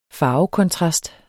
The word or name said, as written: farvekontrast